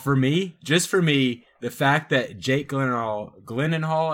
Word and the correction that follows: Gyllenhaal: I guess is that how you say it? Gl- Gyllenhaal. Gyllenhaal. The fact that yep.